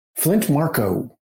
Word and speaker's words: Flint Marko: Did you guys say his name is Flint Mace? – Flint Marko. – Flint Marko. – Flint Marko. Huh.